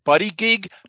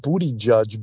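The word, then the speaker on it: Buttigieg: How the hell do you pronounce his name? – I believe he's pronounced Booty Judge, but I've Booty Judge. Well, it's not spelled that way, so I'm going to call him Buddy Gig.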